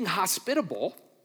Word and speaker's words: hospitable: And what we see here is being hospitable, put the wrong emphasis on the wrong syllable in a second there. Being hospitable is a lot about who you are more than what you do.